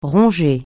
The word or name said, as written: ronger